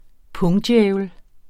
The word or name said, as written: pungdjævel